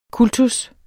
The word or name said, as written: kultus